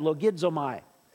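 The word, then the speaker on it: logizomai (λογίζομαι): And in the original, this word is, in the Greek, it's pronounced, it has L-O-G at the beginning of it, logizomai. And so what this means is not log like axemen, like chopping down trees.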